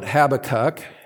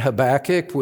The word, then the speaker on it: Habakkuk: And I invite you to turn to the Old Testament book of Habakkuk, and some would call it Habakkuk in other parts of the world. We use the pronunciation Habakkuk with generosity of spirit toward those who pronounce it differently.